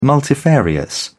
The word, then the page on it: multifarious: British English